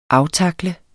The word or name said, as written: aftakle